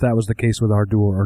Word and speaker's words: Ardour: No, Ardour. Ardour. That's how I pronounce it.